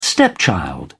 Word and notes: stepchild: British English

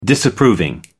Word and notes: disapproving: American English

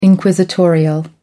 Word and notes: inquisitorial: American English

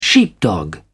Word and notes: sheepdog: British English

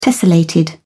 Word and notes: tessellated: British English

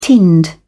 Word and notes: tinned: British English

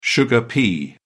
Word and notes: sugar snap pea: American English

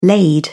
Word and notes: laid: British English

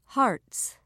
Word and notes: hearts: British English

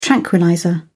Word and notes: tranquillizer: British English

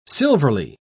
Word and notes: silverly: British English

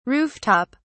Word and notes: roof top: British English

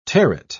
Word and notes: terret: British English

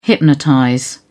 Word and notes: hypnotize: British English

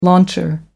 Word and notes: launcher: American English

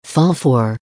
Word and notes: fall for: American English

